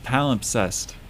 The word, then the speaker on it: palimpsest: Up next, Palimpsest Heart. Am I saying that correctly? – Oh, yeah. Palimpsest.